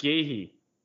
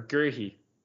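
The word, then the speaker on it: Guéhi: Mark Gahey or Gerhey is the correct pronunciation. – Gehe. I, have heard Gehi. – Gehi, Gerhi. I I've heard both.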